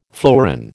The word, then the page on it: fleuron: American English